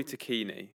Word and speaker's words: Tacchini: Tacchini. – I think it's probably tacchini, to be honest with you, because it's Italian. But we'll just say tacchini because that's the generally accepted pronunciation.